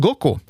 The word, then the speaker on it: Goku: Man uppmärksammar alltså Son Goku, huvudpersonen i serien. Dagen lär vara utvald på grund av att på japanska kan siffrorna 5 och 9 uttalas Go och Ku. Goku alltså.